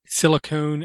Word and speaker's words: silicon: It was make sure you pronounce silicone, and I can't say it. That's how bad I am. – Silicone and silicon. – Thank you. Make sure you say them differently.